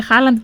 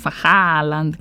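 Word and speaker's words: Haaland: Εμείς το λέμε Χάλαντ και φροντίζουμε να ακουστούν και τα δύο Α. Χάλαντ.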